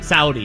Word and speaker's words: Saudi: It's time to learn about Saudi Arabia. I think that's how you say it. Saudi. – Saudi, yes.